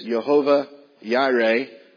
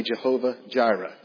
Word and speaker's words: Jehovah Jireh: We know the name Jehovah Jireh, and the correct pronunciation is Jehovah Jireh, but because we are from southern Ohio, I will say Jehovah Jireh.